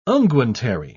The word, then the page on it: unguentary: British English